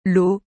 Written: L eau

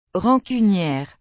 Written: rancunière

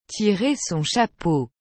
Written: tirer son chapeau

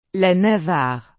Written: la navarre

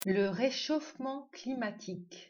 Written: Réchauffement

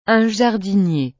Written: un jardinier

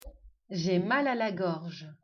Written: Gorge

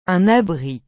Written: un abri

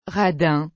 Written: radin

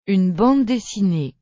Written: une bande dessinée

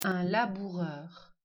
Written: Laboureur

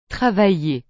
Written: travailler

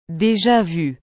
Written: deja-vu

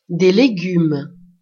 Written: des legumes

